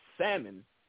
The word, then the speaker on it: salmon: The color is salmon. You actually slightly pronounce the L when it comes to the color, but you don't pronounce it when it comes to the fish.